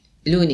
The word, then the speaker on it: Lunae: Lune. In latino non si legge la... – Ah, quindi... – Questo è latino, lune. – Lune, devi dire. – Sì, lune. Cantine lune.